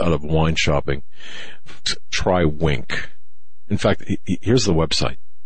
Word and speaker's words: Winc: Now, Wink, it's spelled W-I-N-C.